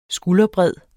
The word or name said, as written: skulderbred